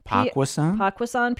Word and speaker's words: Poquoson: Pakusan, is that how you say it? – Pakusan, P-O-Q-U-O-S-O-N, to public address announcer at Rio Olympics.